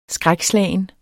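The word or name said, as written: skrækslagent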